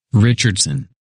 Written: Richardson

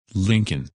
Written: Lincoln

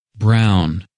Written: Brown

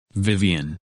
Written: Vivienne

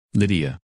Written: Lydia

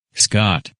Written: Scott

